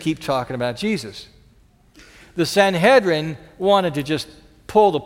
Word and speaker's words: Sanhedrin: Sometime after that, the disciples are hauled in before the high council called the Sanhedrin. Rhymes with Excedrin, just so you know.